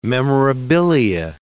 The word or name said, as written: memorabilia